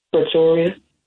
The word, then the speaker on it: Pretoria: Pretoria. – There you go. You said priority.